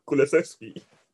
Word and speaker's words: Kulusevski: How do you say his name? – Kulusevski. Yeah, he said it correctly.